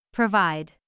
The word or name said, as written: provide